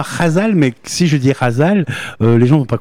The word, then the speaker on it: Ghazal: Oui, alors c'est un mot en arabe et on le prononce avec un R, mais ça s'écrit G-H, Razal.